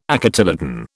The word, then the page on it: acotyledon: British English